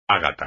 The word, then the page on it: ágata: espagnol